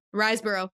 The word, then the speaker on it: Riseborough: But the actors include Christopher Abbott, Andrea Riseborough, is that how you say your name? – Riseborough, yeah.